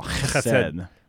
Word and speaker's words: Chesed: To say it like a cat spitting up a hairball. Chesed. Yeah, you're not doing it right.